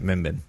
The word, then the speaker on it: Mimban: I still don't know how to pronounce Mimbin. – It. – Mimbin. That's how I say it. Mimbin.